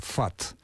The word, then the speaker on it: ФАТ: Ну, например, у нас на факультете, да, на всех филологических факультетах есть дисциплина, которая называется «филологический анализ текста», сокращенно ФАТ. Так вот, эта аббревиатура, состоящая из букв F, A, T, читается именно как слово «фат» и совпадает с известным словом «фат».